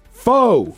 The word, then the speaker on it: pho: Pho. – Pho. – Pho. – Pho. – Pho. – Pho. Pronounced as the Vietnamese themselves do it, pho.